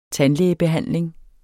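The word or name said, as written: tandlægebehandling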